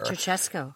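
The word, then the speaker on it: Ceaușescu: It's like Churashkau or something like that, a Romanian communist dictator. – Ceausescu. – There you go.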